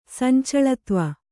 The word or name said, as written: ಸಂಚಳತ್ವ